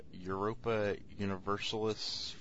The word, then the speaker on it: Europa Universalis: 45, Europa Universalis 4. Did I say that right, Tony? – You did. Congratulations.